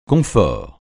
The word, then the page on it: confort: francais